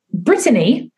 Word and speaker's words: Brittany: It's funny because my name is actually Brittany and my mom is British and she has a British accent. So she says it Brittany And so it's spelled T-T-A-N-Y instead of Brittany, kind of like the American version, like Britney Spears.